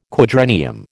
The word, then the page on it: quadrennium: American English